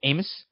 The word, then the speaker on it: Amos: I think that if you're going to keep either Adrian Amos, I keep saying it wrong. It's Amos, Adrian Amos, or Bryce Callahan, I'm going to go with Callahan.